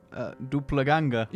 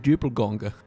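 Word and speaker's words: doppelgänger: So do you know the correct pronunciation of doppelgangers? – Duplaganger. – You're close. It's duplgonger.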